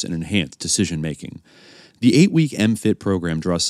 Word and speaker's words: MMFT: military have created and established the mindfulness-based mind fitness training, MMFT, pronounced MFIT, to, quote, "build resilience and optimize individual and team performance."